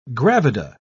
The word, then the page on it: gravida: British English